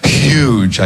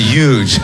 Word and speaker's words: huge: You know, like when I say huge, I don't pronounce the H, she said, and it annoyed her. Like I should be saying huge, I guess, instead of huge.